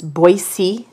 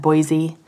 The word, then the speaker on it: Boise: I was going to say the proper pronunciation is Boise, not Boise, which I made the mistake many times.